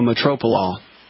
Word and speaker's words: metoprolol: One is clonidine, one is hydrochlorite, one is metrolol. I can't pronounce it. Metoprolol. – It's called metoprolol.